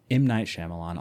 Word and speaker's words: Shyamalan: M. Night Shyamalan, I'll say it right.